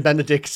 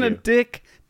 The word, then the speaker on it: Benedick: Just it's bailing out on the name Benedict far too early in my view. – Benedick. D-I-C-K is how it's spelled.